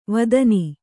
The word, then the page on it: ವೇದನ: vēdana